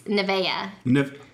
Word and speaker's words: Nevaeh: I mean, that's not, if you're trying to pronounce it how it's spelled, it's not Nevaeh. But that's how everybody that names their kids Nevaeh, they pronounce it Nevaeh.